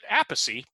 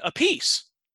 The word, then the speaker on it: Appice: And I can't remember if she said dad or granddad, but I one of the two, she said, pronounces it Appice, which is the original Italian. Carmine pronounces it Appice, so we were right on that. And Vinnie pronounces it Appice, and she summed it up with, nobody knows why, not even them.